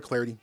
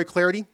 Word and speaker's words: Clardy: Hi, I'm Troy Clarity. Great to have you with us. Follow me on Twitter, at Troy Clarity. Last name is spelled C-L-A-R-D-Y, at Troy Clarity is the way to go.